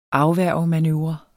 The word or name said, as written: afværgemanøvre